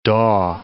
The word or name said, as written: daw